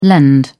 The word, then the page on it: lend: British English